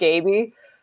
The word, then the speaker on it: Gabey: I feel I feel obliged to correct you, even though that's like super annoying that it's actually pronounced Gabey.